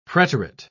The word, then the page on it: preterite: British English